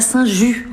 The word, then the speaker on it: Saint-Just: On est à Saint-Just.